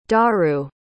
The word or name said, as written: daru